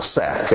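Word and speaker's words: sack (שק): Because you've got a shin and a kof, which spells the word sack. Literally, sack. It's also the word sackcloth. And it's pronounced sack in Hebrew, and that's where we get the English word sack.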